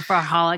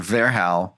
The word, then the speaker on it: Verhaal: It's a Dutch word, Verhal, which has V-E-R-H-A-A-L and has two A's. It's hard to spell. It's hard to say. And I actually said it incorrectly. I was saying Verhal for literally about a year and a half until I was corrected by a Dutch person.